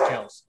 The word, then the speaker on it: Kelce: By the way, we're all saying his name wrong. It's Kels. It's Kels. – Kels. – That was like a big thing. He was on a podcast. He's like, my last name's pronounced Kels, but everybody's called me Kelce my whole life, so I just roll with it.